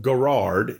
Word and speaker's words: Garrard: It's spelled Garrard, G-A-R-R-A-R-D, but it's pronounced Garrard, 200 West Garrard Road, in Starkville, Mississippi, and we invite you to come and join us for worship 6 p.m.